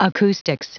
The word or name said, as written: acoustics